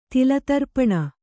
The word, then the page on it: ತಿಲತರ್ಪಣ: Kannada